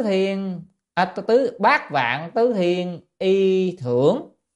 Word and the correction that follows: thường: y thường nhé, chữ này đọc là thường,